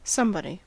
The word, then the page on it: somebody: American English